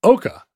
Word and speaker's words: Eochaidh: She marries the king of Ireland, whose name is spelled E-O-C-H-A-I-D-H and is pronounced Eoc. Or, yeah. – No, you said Oka. – Oka. It's pronounced Oka. – That sounds right. – Yeah, Oka. – All the DH stuff goes away.